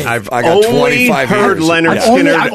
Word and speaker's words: Lynyrd Skynyrd: Debut album is actually titled Leonard Skinner, pronounced Leonard Skinner. – Right. – But the crazy thing about it is it's spelled L-Y-N-Y-R-D, S-K-Y-N-Y-R-D.